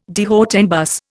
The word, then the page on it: de haut en bas: Indian